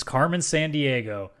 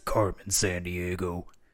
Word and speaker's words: Carmen Sandiego: For the PC in North America, one of my personal favorites, because this is like MS-DOS days, Where in the World is Carmen Sandiego? – Oh, that was such a good game. – Oh, sorry, I'm saying it wrong. Where in the world is Carmen Sandiego?